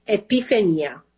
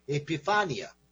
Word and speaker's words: Epifania: First of all, how do you say Epiphany in Italian? – Epifania. – Epifania. Okay. – You say Epifania. – Epifania. Epifania. – Very yes.